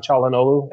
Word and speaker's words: Çalhanoğlu: Can you please correct him on the pronunciation of the name? – Çalhanoğlu. – Thank you. Go ahead. – Çalhanoğlu? – I have no idea. – It's the C pronunciation.